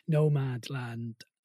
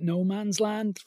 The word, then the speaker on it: Nomadland: It's one I could easily mispronounce. I keep going to call it No Man's Land from Indeed. – The seminal Billy Joel song of 1993, but it's Nomad Land.